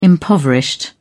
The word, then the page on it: impoverished: British English